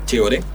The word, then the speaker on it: Che Goré: Tenemos una fanpage de Facebook, digamos, que es Chegore, con acento en la E. – En la última E. En la E de Gore.